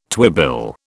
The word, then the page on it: twibill: British English